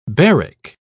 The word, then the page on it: baric: British English